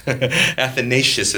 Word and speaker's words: Athanasius: Athanasius, not Athanasius. Athanasius is how you pronounce his name.